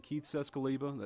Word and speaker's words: Keith Czeskleba: I'm Captain Keith Sescaliba, that's spelled K-E-I-T-H-C-Z-E-S-K-L-E-B-A, with the Hoover Police Department.